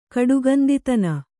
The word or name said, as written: ಕಡುಗಂದಿತನ